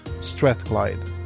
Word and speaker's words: Strathclyde: Marc was born and raised in Glasgow, and he completed his master's and Carnegie Trust-sponsored PhD in chemistry at the University of Strathclyde. Is that how you pronounce it? – That's perfect. Got it in one.